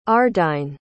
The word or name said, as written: ardine